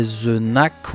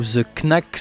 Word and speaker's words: The Knack: Le groupe, c'est The Knack ou The Knack, selon le pays d'où vous venez, niveau prononciation.